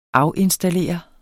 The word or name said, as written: AFINSTALLERE